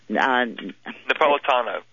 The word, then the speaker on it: Napolitano: Napolitano.